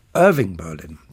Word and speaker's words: Irving Berlin: Aber Irving Berlin, der Komponist, der wird vorne betont.